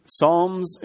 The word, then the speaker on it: Psalms: Okay, so I'm going to talk a little about Psalms and Proverbs. For those of you who didn't know, Psalms begins with the letter P, but you don't pronounce the P. Otherwise, it'd be Psalms.